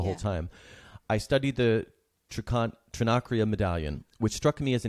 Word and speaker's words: Trinacria: But I want to read one quote that you have about the Trinacria.